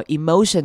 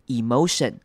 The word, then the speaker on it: emotion: 好， emotion 这个字要特别注意，你有三种念法，你可以念成长母音 e emotion，或者是你可以念成短母音 i emotion，或者是你可以念成轻音 emotion，三种都是可以的。